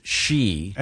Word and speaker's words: Xi: They spell it X-I. – Yeah. – But it's pronounced, this says it's pronounced she.